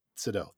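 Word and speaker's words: Seydoux: And you fucking take great, you take great actors like Leia Sadeu, I don't know how to say her fucking Sadeu. – Sadeu, thank you.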